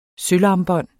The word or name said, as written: sølvarmbånd